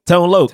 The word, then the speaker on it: Tone Loc: How do you say his name? – Tone Loc. – Tone yeah. – Loc. – Tone Loc. Oh, Tone Loc.